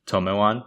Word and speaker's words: Tome-wan: Tome-Wan? Is that how you pronounce it? – Tome-Wan? Yeah. Yeah.